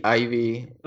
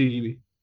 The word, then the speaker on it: Eevee: ¿Es Eevee, Ivy? – No, ah, Eevee, sí, Eevee.